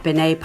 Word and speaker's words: Binette: That's at Binet Park. Actually, I think Ed pronounced it Binet Park. So if you're looking it up for directions, it's spelled B-I-N-E-T-T-E Park, and it's on Main Street in Old Town.